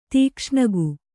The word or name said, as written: ತೀಕ್ಷ್ಣಗು